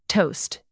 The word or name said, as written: toast